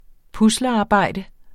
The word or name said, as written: puslearbejde